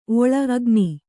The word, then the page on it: ಓಳಿ: ōḷi